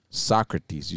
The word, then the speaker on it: Socrates: Socrates, you said that's how you mentioned that, right? – You're right. – That's how you pronounce it. – Exactly right.